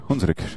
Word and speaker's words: Hunsrück: Hunsrück, ja, richtig ausgesprochen.